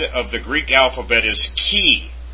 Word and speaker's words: chi: In ancient Greek, keep on reading, the letter chi was probably pronounced like the CH in Scottish. Lach or German Bach. Since this sound does not occur in the English, the chi sound in chemist may be used instead.